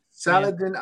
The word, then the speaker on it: Saladin: The cover artist is Christopher Allen, and the writer is Saladin Ahmed. – Yeah. – Saladin Ahmed. – Yeah. Drink! I got it. I said salad, but whatever.